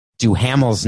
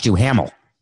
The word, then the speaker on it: Duhamel: Apparently, a listener corrected me because I pronounced Josh Duhamel's name Josh Duhamel, but apparently it's Josh Duhamel.